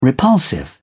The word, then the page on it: repulsive: British English